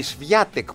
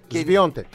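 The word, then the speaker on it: Świątek: Η Σβιάτεκ που γράφεται. – Σβιόντεκ.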